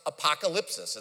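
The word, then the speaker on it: apocalypsis (ἀποκάλυψις): Literally in the Greek, it's pronounced apocalypsis. It's very similar to our English word.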